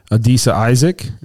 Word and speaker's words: Adisa Isaac: Troy Franklin, Peyton Wilson, Adisa Isaac. Is that how you say it? – Yep.